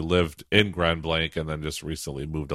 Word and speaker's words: Grand Blanc: Well, this final news story that we've got here is up in your neck of the woods in Grand Blanc. Did I say that right? Grand Blanc, Michigan? – Yes, you said it right, Robert.